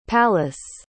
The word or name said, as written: pallas